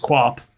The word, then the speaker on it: Quap: So that, like, how do you pronounce the like, one game, Quappy? – Quap? Yeah, just Quap.